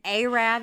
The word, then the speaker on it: Arab: So I graduated high school in Arab, Alabama, and Arab is the correct way to say that.